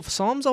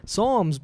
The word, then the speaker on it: Psalms: You know, Psalms always makes me confused because it starts with a P. And I'm like, but that's not an S, though, right? But, you know, English language. – Silent letters. – Psalms, why is there a P?